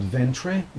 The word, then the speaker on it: Ventre: It's called Ventre, the pronunciation in Portuguese, and Ventre means womb.